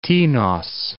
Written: Tinos

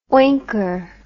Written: oinker

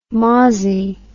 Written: mawsie

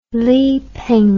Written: lipeng

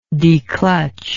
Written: declutch

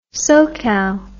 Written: SoCal

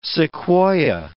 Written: sequoia

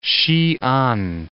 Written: Sian

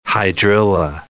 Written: hydrilla

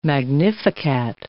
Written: magnificat

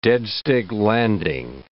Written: dead-stick landing

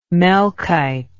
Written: melkite

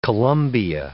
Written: Columbia

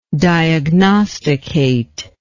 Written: diagnosticate

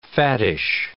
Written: fattish

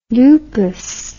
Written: lupous

